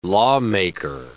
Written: lawmaker

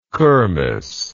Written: kirmess

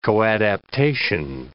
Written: coadaptation

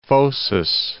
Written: Phocis

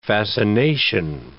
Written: fascination

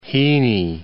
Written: Heaney

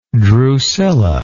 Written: drusilla

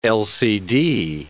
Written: LCD

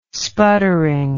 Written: sputtering

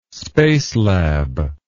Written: spacelab

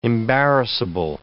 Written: embarrassable

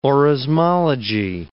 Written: orismology

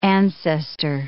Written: ancestor

